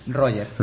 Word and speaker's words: Roger: Roger. – Roger, no Roger ni nada, ¿no? – No, por favor. – Pero Roger sería catalán, ¿no? – Exactamente. Entonces, soy gallego.